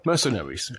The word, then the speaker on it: Mercenaries: Wie spricht man das eigentlich aus? Mercenaries? Egal. – Mercenaries, ja, aber.